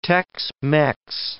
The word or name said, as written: Tex-Mex